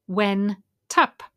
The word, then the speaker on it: went up: Went up sounds like when tup because the T moves.